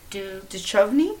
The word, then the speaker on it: Duchovny: Forbes, Tennessee, Michelle Forbes, um, Davidson Mine, Dew Cove, which was David, I don't know how to say his last name, DeConfi. Duchovny. Duchovny. Duchovny.